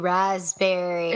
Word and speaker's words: raspberry: Ooh, raspberry. – Is that like, why don't we pronounce the P in that word?